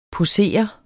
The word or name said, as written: POSERES